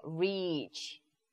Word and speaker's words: reach: R-E-A-C-H. – 아, 그렇습니다. 자, 그럼 발음 한번 구분해 볼까요? R-I-C-H는 어떻게 발음하죠? – Rich. – 짧아요. – Rich. – Rich. Rich. 하지만 R-E-A-C-H는? – Reach. Reach. – 너무 길죠? Reach. Reach 이렇게 구분하시면 되겠네요.